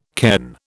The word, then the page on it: cairn: British English